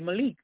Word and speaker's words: Malik: So when he did what he was supposed to do for me, I said, how do you pronounce that? He said, Malik.